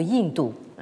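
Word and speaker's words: Yinto (印度): qui se prononce en désignation moderne sous le nom de Yinto. Hein, Yinto, c'est donc le nom moderne que les Chinois donnent à l'Inde.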